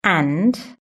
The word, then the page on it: and: British English